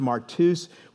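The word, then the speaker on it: martus (μάρτυς): It's pronounced martus. We get our word martyr from this word.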